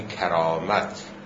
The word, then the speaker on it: کرامت: البته این را هم عرض کنم، این که ما اینطور تلفظ میکنیم، تلفظ درست تمام این واژه ها، یعنی کرامت و سخاوت و شرافت و نفاست، به فتح اول است. یعنی کرامت، سخاوت، شرافت، نفاست باید به فتح اول تلفظ بشه.